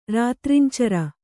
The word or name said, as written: ರಾತ್ರಿಂಚರ